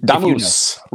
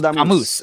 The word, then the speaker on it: Damus: Can you can you share with us what's the correct way to say his last name? – Damus, you know? Ronaldo Damus.